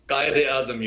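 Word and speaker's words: Quaid-i-Azam: Can you say the name of your university? I'm sorry, I mispronounced it. – It's Qaid-e-Azam University.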